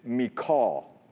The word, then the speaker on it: Michal: And now God is going to use David's wife, who, by the way, is also the daughter of Saul, whose name is Michal. That's how you pronounce her name in Hebrew. It's Michal.